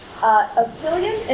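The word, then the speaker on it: epyllion: Aprillion, and thank you for asking.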